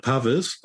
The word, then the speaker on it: Pavis: But so I would pronounce it Pavis. – Mm-hmm. – But I really like, I think it was maybe Chris Gidlow who said to pronounce it Pavi. And I like that. I like that because it's got a little bit of Frenchness in it.